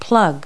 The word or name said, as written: plug